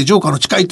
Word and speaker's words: 城下の誓い: なのに、その中国で第一段階の合意について、国家主席の習近平がトランプ大統領に屈服した浄下の誓いではないのかあの、お城の下の名優の名と書いて城下の誓いと読むらしいんですが、ええ、そういう議論が盛んなんだそうです。